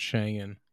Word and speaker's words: Schengen: I believe it's pronounced Schengen.